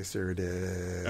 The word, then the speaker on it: Cyrodiil: Yes, there's two I's in Cyrodiil. That's why I say Cyrodiil.